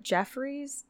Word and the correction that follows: Joffrey's: Donuts. It's like... – Joffrey's. It's their coffee. – That's what it is. Joffrey's.